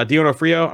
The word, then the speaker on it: D'Onofrio: So yeah, um, Daredevil Born Again, uh, Vincent D'Orfornio, uh, D'Onofrio. I'm sorry, I keep, butch- I keep butchering his last name.